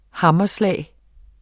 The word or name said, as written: hammerslag